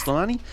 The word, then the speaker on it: Slimani: All right, the book is Adele by, how am I pronouncing it? Leïla Slimani? Yes. Slimani.